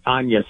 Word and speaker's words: Agnes: spelled like Agnes, but Anya.